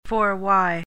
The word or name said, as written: forwhy